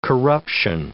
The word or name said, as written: corruption